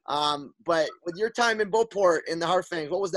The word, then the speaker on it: Beauport: But before I get to there, your first year in, am I saying it right, Beauport? – Yeah, Beauport, yeah.